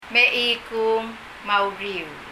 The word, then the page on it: Mechikung me a uriul: mɛ?iku:n məuri:ul